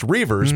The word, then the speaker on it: Reuvers: So my last name is Reuvers. – So Reuvers. – Even though it's pronounced Reuvers, but there's that stupid U that's right there.